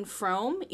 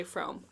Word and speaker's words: Frome: Yeah, so the first one we read was, controversial pronunciation, Ethan Frome. – I've always called it Ethan Frome, but to be fair, I said Ethan Frome, and then I got to class and everyone started saying Frome, and I was like, I guess we're just going to say Frome.